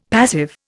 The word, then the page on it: passive: Indian